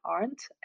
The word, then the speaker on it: Arndt: And that's under my name, Julia Arndt. And if you have trouble to spell that, it's A-R-N-D-T.